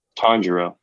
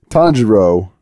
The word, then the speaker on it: Tanjiro: Like, for example, was it Tahiro? Is that how you say his name? Tahiro? – Tanjiro. – Tanjiro, sorry. Tanjiro. Yeah, like he is voiced by Natsuki.